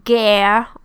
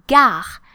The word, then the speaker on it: gare: I often hear guerre or like something. La guerre is the war. So it's totally different. Don't forget that the letter A in French is called A. So it's pronounced A. And therefore we say la gare, not la guerre, right?